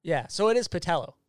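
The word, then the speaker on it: Pattullo: How is it pronounced? What's the official pronunciation? – I would go with Patello. Like, people will call in and they'll say Patullo, and I'm always just like, that just doesn't sound right.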